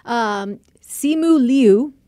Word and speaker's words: Simu Liu: And one of the big names on here, we just saw him in Shang-Li, Simu Liu is how you say his name.